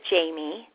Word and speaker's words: Je t'aime: Now, I have a quick question for you, because it says here in your bio that your name is pronounced like I love you in France. So how do you pronounce your name? – Well, I pronounce it Jamie, okay. But it's spelled Je t'aime, which is French, and my mom speaks French, and so she named me, she spelled it that way.